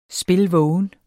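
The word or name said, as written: SPILVÅGENT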